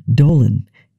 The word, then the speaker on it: Dowland: The surname of lutenist and composer John Dowland had a slightly different pronunciation, Dolan, in Elizabethan English,